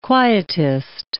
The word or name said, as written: quietist